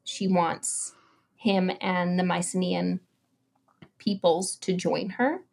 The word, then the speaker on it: Mycenaean: Mycenaean. – Mycenaean.